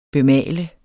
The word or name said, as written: bemale